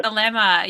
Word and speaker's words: Thelema: I never remember how to say it. – Thelema.